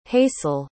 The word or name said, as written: hasel